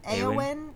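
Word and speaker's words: Éowyn: Eowyn, yeah.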